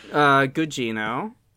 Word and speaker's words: Gugino: Carla Gugino. – Drinking game for this episode, everyone. Every time we say the Weasel, you gotta drink. – Yeah. Correct pronunciation on Gugino. Well done.